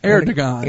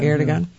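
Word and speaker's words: Erdoğan: And then you have Turkey, Erdogan. Is that how you say his name? – Erdogan. – Erdogan. Yeah.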